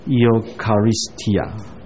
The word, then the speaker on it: Eucharistia: 可是在希腊文里面 EU 这个字母读作 EO，颠倒读法 EO 不是读作 OI， Eucharistia 这个字，这个字的意思你知道我们在讲恩典恩赐 charisma 这个字，所以用来讲圣餐的这个字其实跟用来讲恩赐的字是同样的字根的。